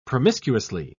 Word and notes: promiscuously: British English

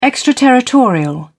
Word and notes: extraterritorial: British English

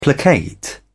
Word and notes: placate: British English

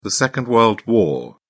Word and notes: second world war: British English

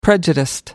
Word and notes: prejudiced: American English

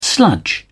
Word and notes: sludge: British English

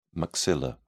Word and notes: maxilla: British English

